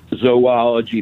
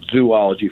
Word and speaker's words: zoology: Well, no, as mentioned before, one of the most mispronounced words is zoology. People add another O to make it zoology for some reason.